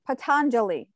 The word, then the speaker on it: Patanjali: There's this, in the Yoga Sutras of Patanjali, I always say his name wrong. Patanjali. I'm sorry. I always add an extra N.